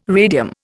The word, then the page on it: radium: Indian